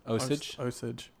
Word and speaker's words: Osage: August, how do you pronounce it? – Osage County, which was nicely made.